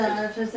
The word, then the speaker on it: Donatists: Donatists, is that how you say it? – Right there. – Yeah. – See there? See Novatians and then Donatists. – The Donatists, and, okay.